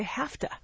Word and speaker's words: have to: In conversation, we run them together, and we pronounce them very quickly, and we say have to. – Like H-A-F-T-A.